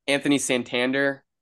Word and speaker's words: Anthony Santander: Anthony Santander, he's been hitting the living daylights out the ball. Is that his name? That's his name, yeah. – right? – Yeah, yeah, you said it right.